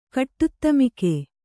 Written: ಕಟ್ಟುತ್ತಮಿಕೆ

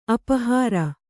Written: ಅಪಹಾರಿ

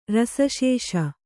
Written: ರಸಶೇಷ

